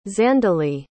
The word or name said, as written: Zandeleigh